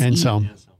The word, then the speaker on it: Anselm: A-N-S-E. – Anselm.